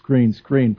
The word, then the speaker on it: Skrine: And it's funny, you know, I learned to always pronounce Skrine from listening to your show. But I know that it's phonetically it's Skrine, and Maybe this is a rookie initiation, but one of the coaches the other day said, "Hey, Skrine."